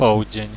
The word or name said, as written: powdzien